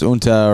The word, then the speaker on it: Junta: Junta. That's pronounced Unta or Junta.